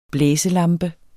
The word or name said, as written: blæselampe